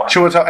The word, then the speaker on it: Chiwetel: Chiwetel Ejiofor.